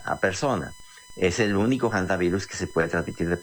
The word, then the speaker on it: hantavirus: Ahí está en efecto ese virus que mencionas, de estos hantavirus o antivirus, se escribe con H y se puede pronunciar en español como antivirus, pero es muy conocido internacionalmente como hantavirus.